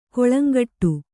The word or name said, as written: ಕೊಳಂಗಟ್ಟು